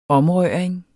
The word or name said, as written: omrøring